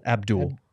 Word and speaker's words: Abdel: Is it Abdel or Abdul? – Abdul. – Abdul. It is Abdul. – It's spelled Abdel, okay, Abdul. But it's pronounced Abdul.